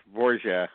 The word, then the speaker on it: Borge: You know, aren't they making like a Victor Borge biopic? – Are they? – Borgia? – I guess it's Borgia, how you say it.